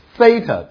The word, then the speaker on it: theta: 一二三四五六七八，现在马上要讲了，是 theta，它是子音，它的发音呢有点英文的 th 这种发音，啊，th，所以像我们英文 think。